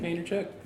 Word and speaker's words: Vaynerchuk: Vaynerchuk.